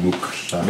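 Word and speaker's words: muqw'lha: Mukla or mukla? – Mukukla. – Mukla? – Mukukla. No. Mukukla. It's QW apostrophe. Mukukla. See, everyone, we grew up saying it wrong.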